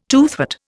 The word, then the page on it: toothwort: Indian